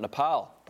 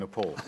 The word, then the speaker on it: Nepal: We've got a Socceroos match as well, and they take on Nepal. I've been told that's how you say it. – Well, I'm going to be saying Nepal.